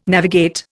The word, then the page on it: navigate: Indian